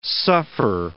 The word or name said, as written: suffer